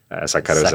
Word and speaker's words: sacarosa: Sacarosa. – Yeah. – Did I say that right? – Sacarosa.